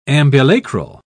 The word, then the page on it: ambulacral: British English